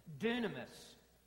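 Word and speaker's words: Dunamis (δύναμις): I'm going to pronounce it because you will recognize an English word in it. Dunamis, dynamite. A miracle is a display of the dynamic power of Almighty God.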